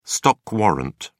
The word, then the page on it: stock warrant: British English